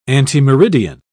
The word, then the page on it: antemeridian: British English